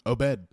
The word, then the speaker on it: Obed: Obed. – Obed, like O-B-E-D? – Yeah.